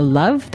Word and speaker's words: Luv: or Facebook at DJ Cherish the Love, that is spelled L-U-V, and hashtag using Primary Food, or Heritage underscore Radio, and hashtag RevLove.